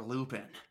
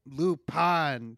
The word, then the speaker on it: Lupin: It's Lupin, sir. – What? – His name. It's pronounced Lupin. – But why? It's spelled L-U-P-I-N. – Just trust me on this one, sir. Everyone says Lupin for some reason.